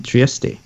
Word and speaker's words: Trieste: Fun fact, that town can be pronounced both Trieste and Trieste, depending on whether you are pronouncing it in German or Italian.